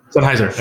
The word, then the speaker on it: Sennheiser: And I have, usually I use the headphones, which is the, I think it's the Sennheiser or something like that. – Sennheiser. – There you go. So you pronounce them.